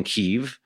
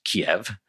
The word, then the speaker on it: Kyiv: Before Russia invaded last year, if you'd asked, I'd probably have been able to name something like three Ukrainian cities, including Kyiv, which I probably would have pronounced Kiev.